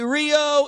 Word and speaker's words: Rio: And if you say it like the Portuguese, R's are H's. So I'm going to tell you how you say Rio in Brazil. It's Rio.